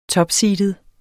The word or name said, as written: TOPSEEDET